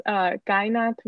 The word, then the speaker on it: Kainath: So my TikTok is Kainath Murr because it's spelled K-A-I-N-A-T-H and then M-E-R.